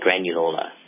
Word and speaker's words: granuloma: Young lady, friend of mine, has been diagnosed with granuloma. Did I say that right? Granuloma.